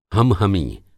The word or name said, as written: हम-हमी